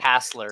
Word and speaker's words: Hassler: This is where we get the sucky David Haskell. Am I saying the name right? I keep thinking it. – Hassler? Different. – Hassler, thank you. Yeah. Hassler.